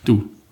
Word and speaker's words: Doubs: Doubs und alle französischen Worte lassen sich mit zwei Buchstaben aussprechen. – Du.